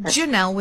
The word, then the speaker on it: Janelle: Is it Janelle with a hard J or Janelle with a...